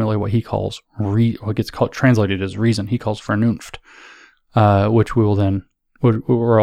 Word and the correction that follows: Vernunft: also going to spend some time on that later, and I mispronounce it, it's Vernunft. I'm working on it, my German.